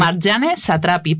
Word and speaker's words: Marjane Satrapi: A ver si me ayudáis a pronunciarlo. – Varias veces lo he leído. – Marjane Satrapi, ¿puede ser? – Sí, algo bueno, así.